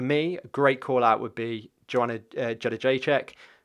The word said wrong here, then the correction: Jędrzejczyk: Joanna Jędrzejczyk, sorry, get the name right.